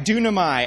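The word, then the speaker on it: dunamai (δύναμαι): That's right, dunamai.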